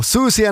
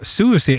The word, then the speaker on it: Siouxsie: Den här gången, mycket intressant, vi hade stora svårigheter med att uttala det rätt här på redaktionen. Men till slut fick vi det på Susie and the Banshees. – Mm, för Susie i det här fallet stavas S-I-O-U-X-S-I-E.